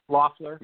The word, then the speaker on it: Loeffler: Politically, I think your Senator Kelly Loeffler, is that how you pronounce her name? Loeffler? – Loeffler, I believe. – Loeffler? – Yeah, Loeffler.